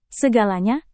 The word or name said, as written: segalanya